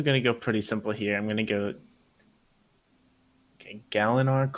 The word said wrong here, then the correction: Gavilar: colon. – Hold up. Whoa. Back up. – Wait, did I say it wrong? – Yep. Elliot, give him the pronunciation, or give him how you would say it again. – Okay. – Gavilar. – Okay, so it's just Gavallar.